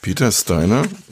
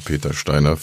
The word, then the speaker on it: Peter Steiner: Peter Steiner, so wird er jetzt natürlich auf amerikanisch-englisch ausgesprochen, also Peter Steiner für Leute, die nicht wissen, wie Englisch geht.